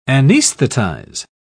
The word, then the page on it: anaesthetise: British English